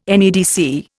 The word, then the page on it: NEDC: Indian